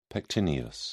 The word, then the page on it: pectineus: British English